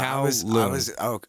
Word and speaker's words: Kowloon: Oh, Kowloon. But Kowloon.